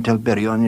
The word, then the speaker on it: Telperion: Laurelin and Telperion, yes, the golden... – Telperion, the rolling of the R's.